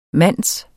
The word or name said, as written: mand